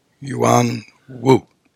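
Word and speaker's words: Yüan-wu: Yuan Wu.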